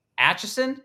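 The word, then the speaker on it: Aitchison: Doug Acheson. Am I saying it right? – That's it. Acheson. – Damn. Acheson at D-Acheson 23. That's A-I-T-C-H-I-S-O-N 23.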